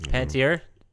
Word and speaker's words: Panettiere: She was also voiced, by the way, by Hayden Panettiere. Is that how you pronounce her last name? – Panettiere. – Mm-hmm. Panettiere.